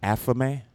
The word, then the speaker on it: athame: And so, how do you say that again? What's it, what is Athame. it? Athame.